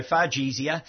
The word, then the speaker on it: Fargesia: Better spell that. – F-A-R-G-E-S-I-A. – Oh, as it says, Yeah, more or less as it's pronounced. Yes. Yeah, so Fargesia.